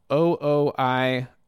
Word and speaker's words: OOIOO: This is a band called OOIOO. That's how you say it. Two O's and I and two more O's.